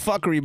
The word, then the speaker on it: Fockery: I think we should call it 15 minutes of fuckery, but spell it F-O-C-K. – That's hard. – That's fire. – Fuckery. – You know, like Meet the Fockers? – Yeah.